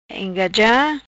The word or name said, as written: Engaja